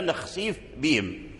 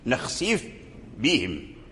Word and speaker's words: نخسف بهم: أظهر الفاء عند الباء في قوله تعالى إن شاء نخسف بهم، نخسف بهم، نخسف بهم، إذن لا بد من بيان الفاء، لا بد من بيان الفاء ثم عاد تنطق بالباء، نخسف بهم، والفاء خفية والباء شديدة، والباء هي أقوى من الفاء، وإذا اجتمع حرف ضعيف مع حرف قوي فلا بد من إظهار الحرف الضعيف عند الحرف القوي